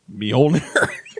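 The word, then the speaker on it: Mjölnir: It's pronounced Mjolnir.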